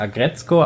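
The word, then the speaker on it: Aggretsuko: Das Ding ist auch auf Netflix, heißt Agretzko. Mhm. Geht gerade voll durch die Wand. Also das schreibt man Agretzuko, aber man spricht Agretzko aus. – Agretzko.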